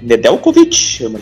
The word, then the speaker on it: Nedeljkovic: Realmente, o Nedeljkovic, já é essa a pronúncia do goleiro do Detroit, Carolina deixou ele passar.